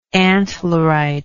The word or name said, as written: antlerite